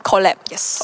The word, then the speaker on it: Collab: OK，我要先来问哦，因为我看到这个名字的时候，我很怕我自己念错，所以这个是念成 collab 吗？collab 设计手。 – Collab，yes。